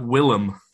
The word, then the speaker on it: Willem: No, his first name. – William. – Nah, Willem. – Is that how you say it? The fuck? Willem Dafoe. – Yeah, not William Dafoe.